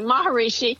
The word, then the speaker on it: Maharishi: How'd you get with Mr. Maharishi, his second name? I can't pronounce. – No, you pronounced it correctly. Maharishi.